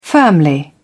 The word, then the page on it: firmly: British English